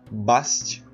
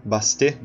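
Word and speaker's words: Bastet: E uma coisa bastante interessante é que no Egito existia uma deusa chamada Bast, ou Bastê, na verdade, né? Que se pronuncia.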